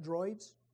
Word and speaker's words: Druids: Am I pronouncing that right? D-R-U-I-D-S? Is that Druids? Druids, okay. The Druids of in Northern Europe, they believed mistletoe had curative power and thought it could even cure broken relationships.